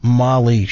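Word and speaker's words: Magli: It was from a size 12 Bruno Mali shoe. It's M-A-G-L-I, but I think it's pronounced Mali. The G is silent.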